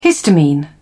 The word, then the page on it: histamine: British English